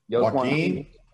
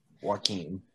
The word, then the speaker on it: Joaquin: Joaquin the hell spells Joaquin with a J? – The J is silent.